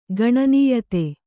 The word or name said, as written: ಗಣನೀಯತೆ